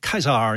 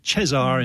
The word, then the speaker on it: Caesar: Like in Caesar, you know, it's pronounced Caesar in classical Latin and Cesar in medieval or church Latin. But of course, we say Caesar, of course.